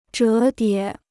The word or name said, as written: 折叠